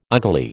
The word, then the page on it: ugly: American English